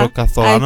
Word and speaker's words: Rocasolano: Așa, Rocasolano. – Ai prins accentul. – Rocasolano? – Rocasolano, S-U-E-S. – Z-U-E-F.